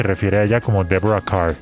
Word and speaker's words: Kerr: Se escribe Kerr, pero se pronuncia Carr por alguna razón.